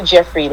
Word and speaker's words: Jeffrie: You can go under Trina Jeffrie. Last name is spelled J-E-F-F-R-I-E.